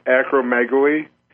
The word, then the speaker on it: acromegaly: How do you pronounce that? – That's no problem. That's actually very typical, Michael. The pronunciation is acromegaly.